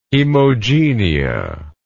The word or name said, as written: hemogenia